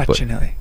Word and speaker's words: Facinelli: So Al Roker tomorrow, Peter Facinelli. I hope I might be mispronouncing that. – Facinelli. Don't say it like Longoria.